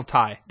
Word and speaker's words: Tigh: right as T is that how you say it, Colonel T? Colonel Tigh. Tigh.